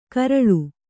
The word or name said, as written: ಗರಲ